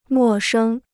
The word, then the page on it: 陌生: mò shēng